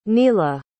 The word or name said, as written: nila